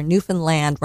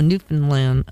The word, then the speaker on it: Newfoundland: I had to learn how to pronounce it. I was corrected when I got there. Newfoundland rhymes with understand. – Oh, Newfoundland.